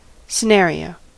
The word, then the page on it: scénario: /se.na.ʁjo/; France